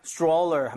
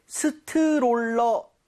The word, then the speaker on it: stroller: 자 근데 발음할 때 스트롤러 아 이건 좀 아닌 것 같아요. – 아쉽네요. – 이 모양을 좀 많이 모아서 R 사운드 제대로 돼서 약간 치읓 비슷하죠. Stroller처럼 네. – 살짝 변하게 여러분들 입모양 모으시고 혀 많이 당겨주세요.